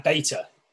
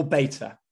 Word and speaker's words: beta: Oh, sorry, beta. – Or beta, yes. – Yeah, because beta sounds like B-E-A-T-E-R, which is like someone who beats people. Whereas beta is how you say it in the ancient Greek.